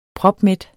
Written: propmæt

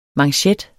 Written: manchet

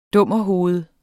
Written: dummerhoved

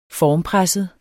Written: formpresset

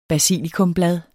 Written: basilikumblad

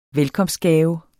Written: velkomstgave

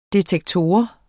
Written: detektor

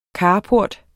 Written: carport

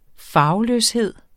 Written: farveløshed

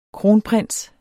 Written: kronprins

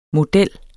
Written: MODELLERNES

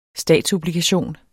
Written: STATSOBLIGATION

